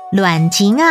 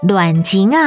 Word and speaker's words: 闹钟: 妈妈把闹钟调到 5点，闹钟台语念作卵琴啊，卵琴啊，客语念作闹钟，闹钟。